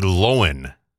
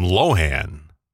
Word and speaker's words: Lohan: I mean, it's spelled L-O-H-A-N. And every time that either one of her parents are interviewed, here comes Michael Lohan. Hi, everybody. Hi, I'm Michael Lohan.